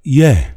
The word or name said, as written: Ye